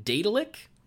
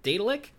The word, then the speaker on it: Daedalic: Our last piece of news is that uh, Lord of the Rings Gollum developer da- Daedalic, I think I said that right, right? There you go. Daedalic.